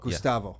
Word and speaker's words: Gustavo: Gustavo.